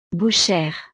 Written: bouchere

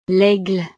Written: l'aigle

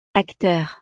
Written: acteur

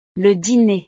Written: le diner

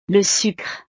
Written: le sucre

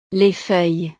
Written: les feuilles

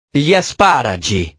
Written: gli asparagi

